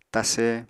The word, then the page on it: tase: Apatani